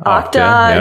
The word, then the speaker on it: Okta: And, Okta. – Right. – Okta. – Okta. – I can never pronounce it. – Yeah, Okta. – Okta and Duo are some of the most well-known.